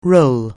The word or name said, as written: roll